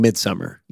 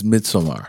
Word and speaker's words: Midsommar: Yeah, which I've just been pronouncing Midsummer. – But that's not how. – I think, you know, the spelling is what? Swedish, Scandinavian. – Ari Aster says Midsommar.